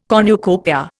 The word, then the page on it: cornucopia: Indian